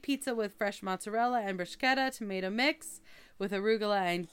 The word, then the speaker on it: bruschetta: I like how you pronounce bruschetta. – What do I say? – Say it. – Bruschetta. – Okay, you said it right that time. – How did I say it before? – you get a K in there somehow.